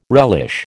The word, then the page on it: relish: American English